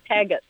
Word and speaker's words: Tagatz: Is it Bob Taggett? – Taggett. – Taggett. Spell that for me, would you? – T-A-G. A-T-Z, Taggett.